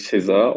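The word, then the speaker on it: Ceesay: genau, bevor die Spieler vorgestellt worden sind, wurden wir aufgeklärt von der Stadionsprecherin, dass er, glaube ich, César ausgesprochen wird, sein Nachname, also Abdoulaye César.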